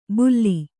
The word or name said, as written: ಬುಲ್ಲಿ